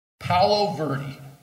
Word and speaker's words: Paolo Verde: Paolo Verde. It's pronounced Paolo, P-A-O-L-O, Verde, V-E-R-D-E. Verde is green in both Spanish and Italian.